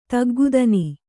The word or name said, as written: ತಗ್ಗುದನಿ